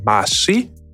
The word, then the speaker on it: Mhasvi: My name is Sam Mhasvi.